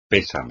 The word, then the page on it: pésame: espagnol